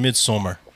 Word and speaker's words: Midsommar: You mean Midsommar? – Oh, my bad. – I'm just saying, say it or don't say it, but. – Well, it's Swedish, I think, so it's pronounced Midsommar.